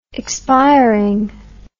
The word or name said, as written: expiringly